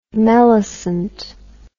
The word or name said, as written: melicent